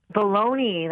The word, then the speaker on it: bologna: First off, pronunciation, Bologna or baloney? – Baloney. That's how we say it in Yale.